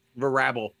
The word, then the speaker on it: Vrabel: Is that how you pronounce that? Vrabel? – Vrabel. – Mieke Vrabel. – Vrabel. – Vrabel. Oh, yeah, Vrabel.